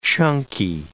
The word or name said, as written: chunky